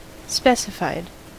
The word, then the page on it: specified: American English